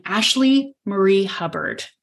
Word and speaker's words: Ashleigh Marie Hubbard: And today we have on Ashleigh Marie Hubbard.